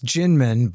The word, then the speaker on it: Kinmen: Okay, so it's Jinmen, but spelled with a K.